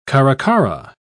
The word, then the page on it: caracara: British English